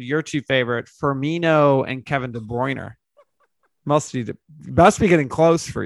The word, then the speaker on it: Kevin De Bruyne: Kevin De Bruyne.